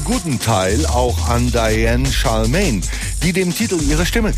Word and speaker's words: Charlemagne: Eine Britin eben mit französischem Namen. Charlemagne, Charlemagne. Sprich es einfach Charlemagne, hat mir nun jemand geraten, den ich heute eigens kontaktierte, weil er einige Jahre in Manchester verbracht hat.